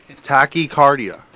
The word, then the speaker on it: Tachycardia: That song was Tachycardia. Tachycardia. I'm trying to pronounce. – Did you say that right? – It. Yeah, the second time.